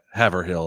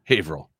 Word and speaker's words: Haverhill: Like everybody mispronounces it Haverhill or Haverhill. It's Haverhill.